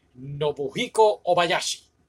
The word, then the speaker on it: Nobuhiko Obayashi: And it's directed by, I'm gonna say this right, Nobuhiko Obayashi.